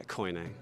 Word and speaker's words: koine: Kini Greek? – Yeah, koine. I call it koine.